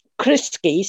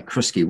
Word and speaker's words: Crisci: But if you know that Jacinda Ardern's other name, or name that she seems to be using in this case, is Criski, C-R-I-S-C-I is how you spell it. – C-R-I-S-C-I. C-I, Criski.